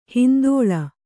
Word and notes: ಹೊಂದಾಳೆ: hondāḷe

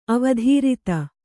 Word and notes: ಅವಧಾರಿತ: avadhārita

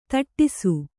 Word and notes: ತುಟ್ಟಿsಸು: tuṭṭisu

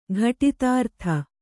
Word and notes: ಘಟಿತಾರ್ಥ: ghaṭitārtha

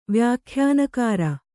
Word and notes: ವ್ಯಾಖ್ಯಾನಕಾರ: vyākhyānakāra

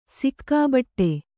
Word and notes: ಸುಖಬಡು: kannada

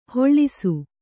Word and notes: ಹೊಳ್ಳಿಸು: hoḷḷisu

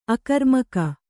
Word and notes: ಅಗ್ರಮುಖ: agramukha

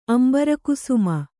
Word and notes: ಅಂಬರಕುಸುಮ: kannada

